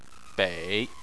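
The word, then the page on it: bei: tone 3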